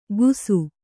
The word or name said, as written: ಗೂಸು